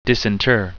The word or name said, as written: disinter